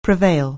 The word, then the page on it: prevail: anglais